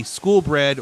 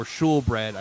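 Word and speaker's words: skolebrød: Most notably, the school bread or shul bread. I've been told it's pronounced shul bread, but it's written school bread on the official menu.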